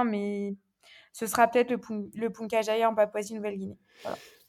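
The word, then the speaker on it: Puncak Jaya: Et en termes d'accès, que le plus difficile, c'est le Punca Jaya, c'est oui. Ça, c'est comme ça que tu le prononces. – Ouais, Punca Jaya, ouais.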